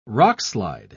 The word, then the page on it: rockslide: British English